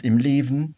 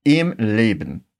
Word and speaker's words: im Leben: Preste atenção que quando nós falamos im, é importante fechar bem os lábios para pronunciar a letra M. Isso é algo bem característico do alemão que não prestamos tanta atenção no português.